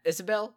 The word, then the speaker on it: Isabelle: is it Isabella? Is Isabelle? I think. – Yeah, Isabelle. – Yeah. Isabelle. – Yeah. Yeah. I was saying her name wrong.